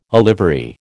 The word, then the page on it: olivary: American English